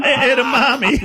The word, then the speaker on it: edamame: Edamame.